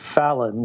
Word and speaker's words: Fallon: It's Edward Fallon, like Fallon. – Jimmy Fallon.